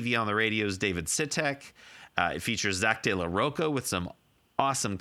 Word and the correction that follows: de la Rocha: Zach actually ends up, by the way, I've been saying his name wrong for 25, 30 years. It's Zach De La Rocha.